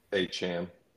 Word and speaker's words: Achane: My best third round picks are Puka and Devin Achane. Achane? No, wait. How do you pronounce it nowadays? – Achane. – Achane, thank you.